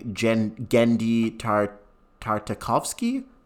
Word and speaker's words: Genndy Tartakovsky: It was directed by Genndy Tartakovsky.